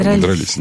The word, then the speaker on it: дрались: Ударение на «и». Там, дрались.